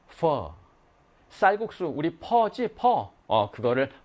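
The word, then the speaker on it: phở: 이제 우리가 배웠으니까 베트남 식당 가면은, 베트남 음식점 가면은, 퍼 주세요. F 발음. 퍼.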